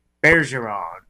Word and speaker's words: Bergeron: So Tyra Banks is announced as the new host, replacing both Aaron Andrews and Tom Bergeron.